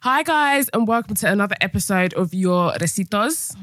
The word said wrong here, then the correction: recibos: Is that how you say it? Recibos.